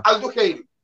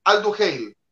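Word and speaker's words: Al-Duhail: Alduheil, Alduheil Aldo Así se pronuncia.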